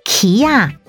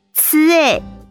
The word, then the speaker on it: 柿子: 柿子台语念作奇亚，奇亚，客语念作吃诶，吃诶。